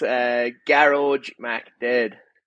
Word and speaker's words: Gearoid Mcdaid: Gearoid Mcdaid.